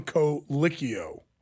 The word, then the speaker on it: Colicchio: Okay, apparently I mispronounced the guy's name and everybody's up my butt about it. It's Tom Colicchio.